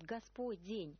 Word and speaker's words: Господень: мягким знаком «без», вот не «Господа» вход, а вот «Господень». И что это за форма такая? – Ну, все-таки правильный вариант с мягким знаком «Господень». Именно так произносится по-церковно-славянски.